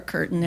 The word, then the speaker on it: Curtin: And my name is Laura Curtin, and it's spelled C-U-R-T-I-N, although people spell my name wrong all the time, so I have the website also with the A in it.